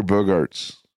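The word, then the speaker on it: Bogaerts: Xander Bogarts. Or Bogarts. I don't know how to pronounce it. Yeah, you said it perfectly the first time.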